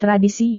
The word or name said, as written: tradisi